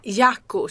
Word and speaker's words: Jakku: Och vi befinner oss med stor sannolikhet på ökenplaneten Jakku. Jag vet inte hur man uttalar ökenplaneter i den här galaxen långt borta, men jag tror det. Vi kan säga Jakku. Jakku. – Jakku, säger man.